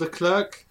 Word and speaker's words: Leclerc: They have Charles Leclerc. Did I get his name right? – Yeah, you did.